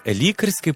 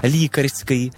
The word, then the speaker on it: лікарський: Як же правильно, лікарський чи лікарський? Зараз заглянемо до словника наголосів української мови за редакцією Миколи Погрібного. Лікарський говорять тоді, коли мова йде про лікаря, а лікарський – той, що має лікувальну цілющу дію.